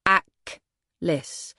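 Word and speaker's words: Achlys: Aklis, pronounced Aklis, Greek goddess of death, misery, and poison, it read.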